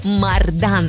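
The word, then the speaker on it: мордан: И сегодня встречаем неодушевленное существительное мужского рода «мардан». Ударение падает конкретно на второй слог и сохраняется во всех формах «мардана», «марданом».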